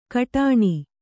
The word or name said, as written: ಕಡಾಣಿ